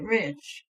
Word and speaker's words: Reich: That was my maiden last name, R-E-I-C-H. And we pronounce it Rich.